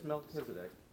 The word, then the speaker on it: Melchizedek: For this Melchizedek, king of Salem. – Nice pronunciation there.